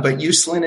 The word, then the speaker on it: Uslan: Yeah, you know, nobody's ever really gotten it exact since my grandfather hit Ellis Island. But Uslan is it.